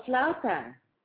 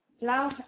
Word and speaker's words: flauta: Florida, some, what I guess they call it a Florida. Some of us call them roll tacos. But. – Oh, flauta. – Whatever. – Flauta, how do you spell it? – F-L-A-U-T-A. – That's flauta.